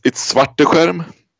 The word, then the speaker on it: Svarteskerm: Yeah, so it's Svarteskerm. So you almost nailed it, like 99%.